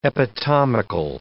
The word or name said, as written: epitomical